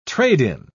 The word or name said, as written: trade-in